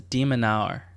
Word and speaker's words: de Minaur: Yeah, the last name is de Minaur.